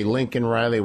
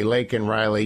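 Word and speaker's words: Laken Riley: You cannot mumble, shout, and scream, and bait, and do things like mispronounce Bobby Kennedy or say Lincoln Riley when you're supposed to say Lake and Riley.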